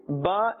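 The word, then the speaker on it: Ba (ب): However, the Ba is pronounced from the wet part of the lips, and the Meem is pronounced from the dry part of the lips. Another thing, the ba is included in the haruf qalqala, and therefore that slight echoing or bouncing sound must be heard.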